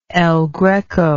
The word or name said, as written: el greco